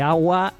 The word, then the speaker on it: Awa: Vale, de Awa, A-W-A, ¿vale? No de agua, que también... – Sí, sí, que el nombrecito de la ciudad en sí. Pronunciación española es agua, ¿sabes? – Sí.